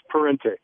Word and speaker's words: Parente: Yeah, and looking through the website at, and is it Sacks Parente? Is that how you say it? – It's Parente. – Parente.